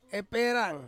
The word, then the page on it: perang: Ansus